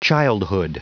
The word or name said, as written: childhood